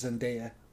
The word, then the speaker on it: Zendaya: Also common mispronunciation. Zendaya is actually how it's. I said it's Zendaya forever too, but it's Zendaya.